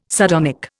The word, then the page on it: sardonic: Indian